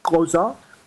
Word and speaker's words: Xhosa: En fait, il faudrait prononcer Kosa, parce qu'on clique la langue. On clique beaucoup. Il y a beaucoup de clics dans ces langues-là, des clics.